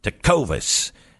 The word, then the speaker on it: Tecovas: You got to say it like George Bush would say. Tecovas. You see what I'm saying? Tecovas.